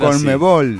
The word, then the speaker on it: Conmebol: Y queda una sigla hermosa, Conmebol. – Es muy buena. – Muy Conmebol. Pronunciable, nada de cómo la pronuncio, C-O-M-B-B-O-L, no. – Hay que hacer Conmebol. – Así. Hay que hacer así.